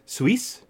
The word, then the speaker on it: Seuss: And the only reason I wrote that down is because people always mispronounce Seuss. It's Suisse. – Huh. – That's just me being pedantic, and I'm sure I didn't even get that right.